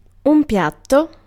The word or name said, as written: piatto